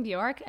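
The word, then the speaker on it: Björk: I remember going to the record store and being like, hi, do you have Bjork? And I was like, I think I pronounced it right. And the guy's like, oh, you mean Bjork?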